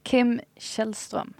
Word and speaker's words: Kim Källström: Kim Källström. – Right, of course. So the K at the start of that word completely... – Yeah, it's a sh sound.